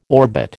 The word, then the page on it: orbit: American English